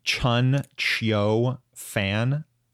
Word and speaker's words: Chen Qiufan: Yeah, you could say Chun Qiu Fan or Fan. – Chun Qiu Fan.